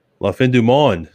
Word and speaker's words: La Fin du Monde: La Fin du Monde. Okay. Sounds like French. – That's what I was told to pronounce it.